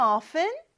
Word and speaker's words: often: 어, 근데 왜 T를 발음 안 할까요? 해도 되고 안 해도 됩니다. 그래서 often이라고 해도 되고요. often이라고 해도 돼요. 그거는 여러분들이 편한 대로 해주시면 돼요. 자, do you visit them often?